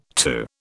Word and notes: too: British English

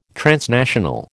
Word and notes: transnational: American English

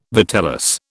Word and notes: vitellus: British English

